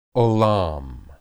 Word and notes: olam: Hebrew

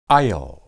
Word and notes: ayil: Hebrew

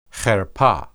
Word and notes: herpeh: hebrew

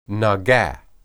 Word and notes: naga: hebrew

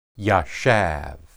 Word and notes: yashab: Hebrew

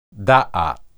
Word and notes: daath: Hebrew

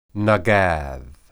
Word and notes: nagad: Hebrew

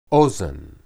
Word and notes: ozen: Hebrew

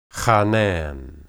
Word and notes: hanan: Hebrew

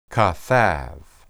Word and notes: kathab: Hebrew

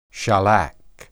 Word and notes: shalak: hebrew

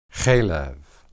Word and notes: heleb: Hebrew

